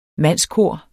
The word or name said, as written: mandskor